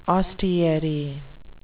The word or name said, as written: ostiary